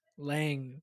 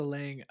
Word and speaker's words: Lange: Lange. Jessica Lange.